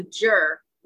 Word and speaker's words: zhi: How do you spell that one? – Z-H-I. – Oh, okay, yeah. I got that one, yeah. – It doesn't sound like it's spelled.